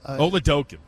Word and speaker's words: Oladokun: So, and also as a Oladokun.